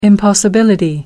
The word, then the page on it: impossibility: British English